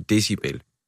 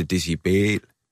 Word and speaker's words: decibel: Det udtales jo en decibel. – Ja. – Og det ville jo være, der er forkert tryk og sådan noget. Så han udtaler decibel.